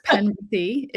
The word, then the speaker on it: Penberthy: And and it's you said the name correctly. It's Penberthy. it's a, a good uh, Cornish name. So if you're from the UK, you would recognize it right away.